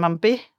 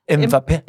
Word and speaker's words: Mbappé: Æh, øh, der lancerede de sådan playmakeren Antoine Griezmann og goldgetteren Kylian Mbappé. – Mbappé.